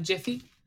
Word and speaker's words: GIF: It's with a soft G, pronounced Jiff. Jiff.